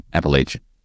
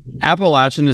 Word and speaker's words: Appalachian: Appalachian. – So Appalachian is technically the right way to say it. Yeah. But Appalachian is what they say up north, which if you're from PA, that makes sense.